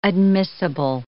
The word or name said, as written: admissible